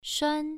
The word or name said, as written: shuan1